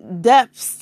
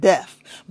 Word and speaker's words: depths: It is depths to this. D-E-P-T-H. Because sometimes I can't pronounce that word right. It might sound like I said deaf, but it's depths to this.